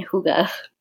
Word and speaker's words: hygge: And yeah, she's pretty good at cultivating that like Scandinavian hygge. – Right. That's how you say it. – Yes, that's how you say it. Hygge.